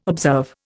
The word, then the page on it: observe: Indian